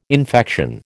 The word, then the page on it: infection: American English